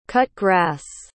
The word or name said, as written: cut-grass